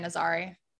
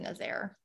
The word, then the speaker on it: Nazaré: Portugal. Yeah, Nazaré. Portugal. I said Nazaire.